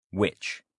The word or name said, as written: which